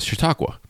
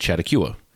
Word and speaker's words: Chautauqua: Chattacua? And I didn't know how to pronounce Chautauqua. I called it Chattacua. I was 13. I didn't know any better.